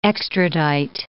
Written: extradite

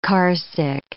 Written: carsick

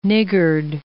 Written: niggard

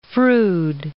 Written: Froude

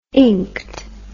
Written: inked

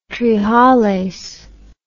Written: trehalase

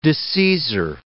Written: disseisor

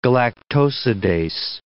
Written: galactosidase

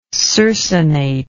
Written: circinately

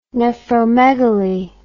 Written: nephromegaly

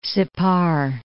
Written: Sippar

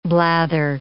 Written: blather

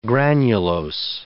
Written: granulose

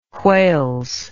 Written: whales

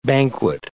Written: banquet

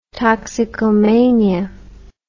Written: toxicomania

